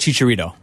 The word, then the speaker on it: Chicharito: Chicharito.